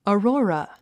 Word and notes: aurora: ɔːrɔ’ːrə; オロゥラ

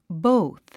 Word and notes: both: bóuθ; ボゥス

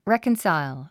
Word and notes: reconcile: rékənsàil; レェコンサァイゥ

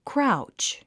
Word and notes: crouch: kráutʃ; クラウチ

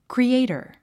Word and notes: creator: kriéitə r; クリエイター

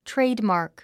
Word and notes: trademark: tréidmɑ̀ːrk; トレイドマーク

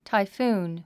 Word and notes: typhoon: taifúːn; タイフーン

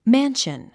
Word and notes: mansion: mǽnʃən; マァンション

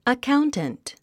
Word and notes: accountant: əkáunt ə nt; アカァウンタント

